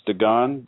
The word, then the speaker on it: Dagon: We have a guy named, I believe it's pronounced Dagon, D-A-G-O-N, Briggs, who does a zombie gimmick.